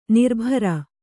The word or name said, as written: ನಿರಭ್ರ